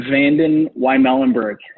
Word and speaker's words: VandenWymelenberg: It's Ben VandenWymelenberg. It's a long one. It's just how it sounds. It's just how it's spelled, but it is long.